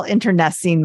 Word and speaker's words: internecine: Okay, just so you know, I did not know what the hell internecine meant. In fact, I had to play Google. How do you even pronounce this thing? I thought it was like nectarine, but it's internecine.